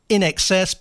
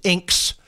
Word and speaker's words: INXS: Now, the quite often poorly pronounced group spelt INXS, pronounced in excess, but sometimes inks, stay young.